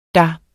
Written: der

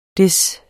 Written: Des

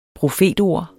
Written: profetord

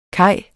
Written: kaj

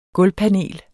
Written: gulvpanel

